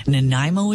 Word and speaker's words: Nanaimo: Nanaimo is the way to pronounce it.